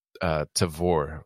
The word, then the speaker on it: Tavour: is using an app called Tavor, which is like the word Savor, but it starts with a T.